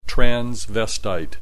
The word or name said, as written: transvestite